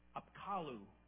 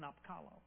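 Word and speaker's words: Apkallu: They had a belief that their god would send messengers called Apkalu, Apkalu-ish, some spelling like that, I don't know. But it's Apkalu is how it's said.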